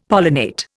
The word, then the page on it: pollinate: Indian